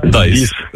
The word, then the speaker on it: dies: Та, тільки англійською дієс, як читається. От пишеться D-I-E-S. Дайс. Та.